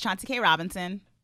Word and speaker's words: Chauncey K. Robinson: Hey, y'all, it's Chauncey K. Robinson.